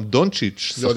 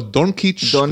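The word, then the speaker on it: Dončić: עזוב את זה, השחקן הכי מפורסם אולי בליגה היום, דונצ'יץ', עדיין קוראים לו דונקיץ' ודונקליץ' ווואטאבר.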